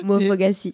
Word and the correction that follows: Moufgas: en effet, je disais Mofogassi, mais c'est pas ça, Moufgas.